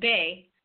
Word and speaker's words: Beth: For the first moon cycles in the Ogham tree calendar, known by the Celts as Beth, mm-hmm. – pronounced Bay, it is the symbol of new beginnings, regenerations, hope, new dawns, and the promise of what is to come.